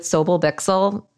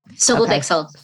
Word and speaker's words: Sobel Bixel: Is a managing director with Sobel Bixel. Did I say that right? – Yeah. Sobel Bixel.